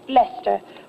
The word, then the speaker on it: Leicester: Well, it's spelled Leicester, but it's pronounced Lester.